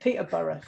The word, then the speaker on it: Peterborough: In the, we pronounce it Peterborough.